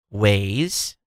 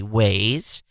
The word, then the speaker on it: Waze: If I'm saying anything from Waze, instead of saying Waz, say Waze.